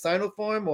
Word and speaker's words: Sinopharm: Is it Sinopharm or? – Yeah.